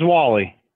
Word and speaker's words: Wally: My name's Wally.